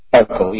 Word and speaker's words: Uccle: Royal Uccle Sports, is that how it's pronounced? – Uccle, yeah, Royal Uccle Sports. I got it right. Yeah. Although I can see it's some funny Uccle or something, but it's Uccle I'll go with.